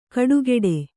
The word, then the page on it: ಕಡುಗೆಡೆ: kaḍugeḍe